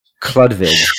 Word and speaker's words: Chlodwig: This was a decision made at the Liebenberg Villa and helped name his successor, get ready for the name, Prince Schlodwig von Hohenlohe-Schillingfürst. That's spelled C-H-L-O-D-W-I-G. Chlodwig or Chlodwig.